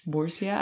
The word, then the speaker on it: Murcia: Murcia. – Thank you. – It's Murcia. I don't know how to say it in English, but yeah, the Spanish say Murcia.